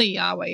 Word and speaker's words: Yahweh: I'll say Yahweh, even though it's spelled with a J and it's getting confusing.